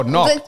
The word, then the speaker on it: knock: Oh, knock. Coburn. So the K is silent in knock. K-N-O-C-K. – Absolutely.